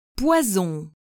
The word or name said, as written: poison